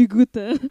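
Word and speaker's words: Goethe: Hute, aduh kok dapet pronunciation gue bahasa Belanda. Di Gute. Di Gute.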